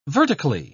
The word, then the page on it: vertically: British English